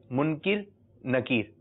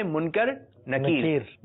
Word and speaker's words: منکر نکیر: کچھ لوگ اس کو یہ کہتے ہیں منکر نکیر تو اس کا صحیح تلفظ آپ ذہن نشین کر لیجئے کیونکہ ابھی سے آپ کی زبان پہ صحیح تلفظ آئے گا تو بڑے ہوں گے تو ایک دم پکا ہو جائے گا تو صحیح تلفظ کیا ہے منکر نکیر